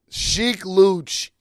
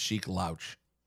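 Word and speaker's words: Sheek Louch: Sheik Looch is incredibly underrated. – Well, first of all, you're saying his name wrong, because I'm reading it. I think his name is pronounced Sheik Louch.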